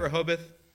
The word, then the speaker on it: Rehoboth: Am I saying that right, Rehoboth? Okay, good.